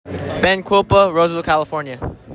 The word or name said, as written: Benjamin Quilpa